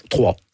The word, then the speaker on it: Troyes: Vous commencez à jouer, bon, vous êtes né à Troyes, on dit Troyes? en France? Comment on prononce? – Trois. – Trois. Mais voilà, ça s'écrit YES en France.